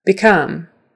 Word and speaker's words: become: By the way, become is actually an syllable, but that syllable is stressed. Become. Become. So that's one of those exceptions. You'd think it would be become, since the com, C-O-M, is a stressed syllable, but it's not. It's not become. It's become. Become.